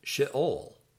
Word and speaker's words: Sheol: Word 30, Sheol or grave, is pronounced Sheol, Sheol, Sheol.